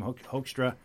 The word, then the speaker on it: Hoekstra: My guest in studio, Master Corporal Glenn Keefe, still an active service member, and Corporal Tim Hoekstra. Am I pronouncing that correctly, Jim? – Yeah, that's right.